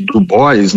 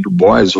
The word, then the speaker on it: Du Bois: Du Bois, né? Algumas pessoas falam W.E.B. Du Bois, ou falam com a pronúncia mais afrancesada, que é o Dubois, William Edward Burroughs Dubois, ou Du Bois, que era a NAACP, National Association of Advanced of Colored People.